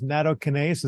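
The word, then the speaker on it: nattokinase: including a product that I believe has natokinase. Is that how you pronounce that? – Yep, correct.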